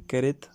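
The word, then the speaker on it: Keret: Continuamos con la siguiente sección, eh, leyendo un relato de Edgar Queret, o Queret, si respetamos la pronunciación española.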